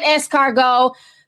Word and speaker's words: escargot: I know how to pronounce that because I took French for several years. he made escargot, told the sister to try it.